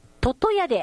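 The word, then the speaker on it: 魚矢: あ、湯村だ、湯村の、魚と矢と書いて読み方は、ととやで合ってますよって。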